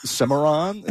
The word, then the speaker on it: Cimarron: And I had this weird recollection of the FSU mascot, Cimarron. Is that how you pronounce it? I think. – Okay, like, that's right.